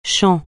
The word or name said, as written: champ